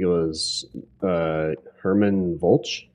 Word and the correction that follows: German Vulch: German Vulch. – Okay. Yeah. So it is pronounced as like the language.